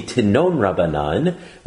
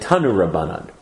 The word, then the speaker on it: Tanu Rabbanan: So it should be Tinon Rabbanan, which gets shortened to Tino Rabbanan, as in to know Rabbanan is to love Rabbanan. – Nice. – But we pronounce it Tanu Rabbanan.